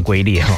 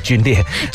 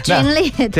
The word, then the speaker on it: 皲裂: 对对对，我猜念成龟裂哦，皲裂。 – 皲裂对。